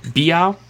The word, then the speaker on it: Biao: So they're basically saying that my boy Lin, how do you say his last name? I'm gonna say Biao all the time unless you say it correctly. Biao.